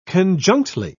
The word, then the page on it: conjunctly: British English